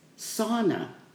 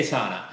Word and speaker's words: Sana: See, I was pronouncing it wrong. It's sauna. It's not Santa. – You know, we're already bastardizing a word. Okay. So like, what is it, sauna or Santa? – We say sauna.